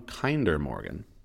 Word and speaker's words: Kinder Morgan: Purchased in 2018 by the Canadian government from Kinder Morgan. That's how you pronounce that. – Wow. – Right? – I always said Kinder Morgan.